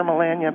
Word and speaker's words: Melania: I call her Melania because, you know what, it's an American way of pronouncing it, and I think she'd appreciate it very deeply.